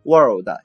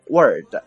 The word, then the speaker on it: world: 那么 Eden 啊在这里面再提醒一下大家 world 这个词的发音问题，有的同学啊经常读成 word w-o-r-d，就是我们说词啊句子这样的含义，那就混淆了啊。这个世界这个词读作 world，中间呢有一个 r 的音，不要给忽略掉。